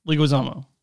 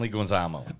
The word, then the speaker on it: Leguizamo: Leguizamo. – John Leguizamo.